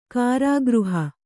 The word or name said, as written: ಕಾರಾಗೃಹ